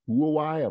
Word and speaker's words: Huawei: Federal Communications Commission, they actually formally announced they'll no longer authorize electronic equipment from Huawei. These different companies, Huawei, I don't know if I'm saying that right.